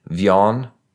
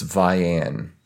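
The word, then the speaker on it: Vian: My mom, when we first moved here, pronounced it Vion, and people laughed at her. It's Vianne. okay, so Vianne.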